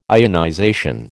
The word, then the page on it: ionization: American English